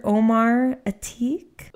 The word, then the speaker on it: Atiq: But it's Dr. Omar Atiq. – Ooh. – A-T-I-Q. – A-T-I-Q? – Yeah.